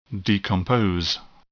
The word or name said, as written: decompose